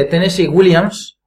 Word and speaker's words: Tennessee Williams: Son obras cortitas de Tennessee Williams. – Pues que lo he dicho bien. – Sí, sí, lo has dicho muy bien.